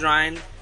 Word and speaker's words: Ryan: Well, my name is Ryan, and begins with R-Y-A-N, and it rhymes with Brian, and I'm fierce like a lion, and I'm flying to the skies.